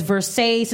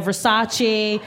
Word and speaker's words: Versace: She can't pronounce designers right. Like she says Versace instead of Versace.